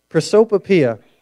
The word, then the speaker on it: prosopopoeia: I had to write down how to pronounce it. The second word, prosopopoeia. Prosopopoeia is how you say that.